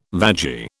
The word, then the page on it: vagi: British English